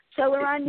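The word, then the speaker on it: Celoron: Janeane Alfred Shanahan is from New York, and if I pronounce her town correctly, Celeron, is that correct, Janeane? Celeron, New York. – Yes, it is.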